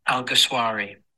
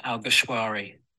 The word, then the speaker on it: Alguersuari: Jaime Alguersuari. So it's Jaime, like, hi mate. And then Alguersuari. Like a sh on Alguersuari or shwari, like a shawarma kebab.